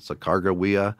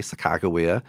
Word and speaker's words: Sacagawea: And that's the only time in the journals that I know of where they actually tried to spell it phonetically with a hard G sound, Sacagawea. Mm-hmm. You know, and then, of course, in North Dakota, they use the K, Sacagawea.